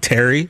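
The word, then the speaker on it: Pterry: He had his short film called Terry, which is spelled P Terry, like pterodactyl.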